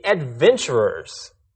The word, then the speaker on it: adventurer: Right, and so we'll be adventurers. – Adventures? – No, listen carefully. Adventurer. An adventurer is a person who seeks adventure.